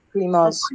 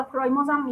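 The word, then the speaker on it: primase: پریماز. – پرایمر یا پریماز کدومش؟ – همون پریماز. – خب آنزیم پریماز یا پرایماز هم میگن تو انگلیسی تلفظ درستش پرایمازه، ما تو فارسی میگیم پریماز.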